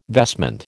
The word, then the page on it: vestment: American English